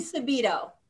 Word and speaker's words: Marly Subido: Today we're joined by Paula Turelli, Executive Director of Mother's Trust, and Marley, did I say it right, Marley? – Marley Subido. – Marley Subido.